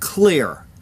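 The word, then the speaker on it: Xlear: The DOJ filed a lawsuit in federal court against Utah-based company, I guess is pronounced Clear, X-L-E-A-R. I don't know how else you'd pronounce that, but it's X-L-E-A-R on October 28th, saying it has deceptively advertised its nasal spray as a treatment and preventative of COVID-19.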